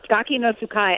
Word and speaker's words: Gaki no Tsukai: Like, I know there's a show called Gaki no Tsukai? I don't even know how to say it. – Yeah, that was right. You were close. Gaki no Tsukai, and I do.